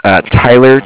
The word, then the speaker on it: Tyler: My name is Tyler, T-Y-L-E-R. I'm calling with Cardiff. It's spelled C-A-R-D-I-F-F, as in Frank. – C-A-R-D-I-F-F.